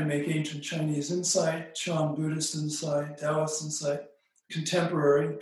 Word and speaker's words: Chan: Chan is the original Chinese pronunciation of the ideogram that in Japan is pronounced Zen.